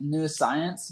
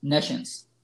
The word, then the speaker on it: nescience: It's like hard to pronounce this because it makes you want to pounce it like new science, but it's actually nescience.